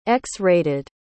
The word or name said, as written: x-rated